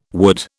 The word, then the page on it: wood: British English